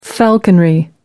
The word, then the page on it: falconry: American English